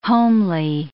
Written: homely

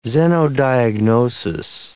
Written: xenodiagnosis

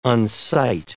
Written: unsight

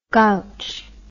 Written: gouch

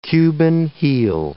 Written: Cuban heel

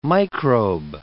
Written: microbe

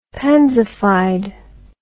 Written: pansified

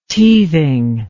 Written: teething

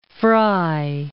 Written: Frye

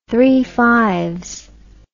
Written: Three Fires

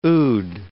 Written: oud

